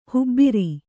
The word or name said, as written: ಹೆಬಾರು